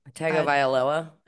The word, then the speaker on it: Tagovailoa: Tagovailoa, I think.